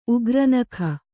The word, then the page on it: ಉಗ್ರನಖ: Kannada